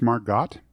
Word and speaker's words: Margot: It's pronounced Margot. – I thought she was Australian. – I don't know. I don't even know if her name actually has a T at the end. – It does have a T at the end, but you do not pronounce it.